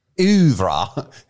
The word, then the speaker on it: oeuvre: For once in the Streaming Things oeuvre, as Cruella would say, we promised something.